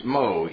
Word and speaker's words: Meaux: In 1521, Farrell went to a town in France, Meaux, M-E-A-U-X, and that's one of the reasons why I don't like French, because they don't spell the words like they pronounce it. M-E-A-U-X, Meaux, France, where he began preaching the gospel of the Reformation.